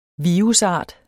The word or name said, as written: virusart